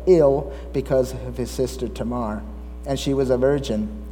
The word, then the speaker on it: Tamar: I used to always pronounce that Tamar, and recently came to the understanding that it's pronounced Tamar. The emphasis is on the Mar, not Te, it's Tamar.